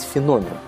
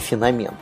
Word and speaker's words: феномен: Э, так вот, этот феномен природный, э, кстати, да, обратите внимание, правильно говорить феномен, а не феномен.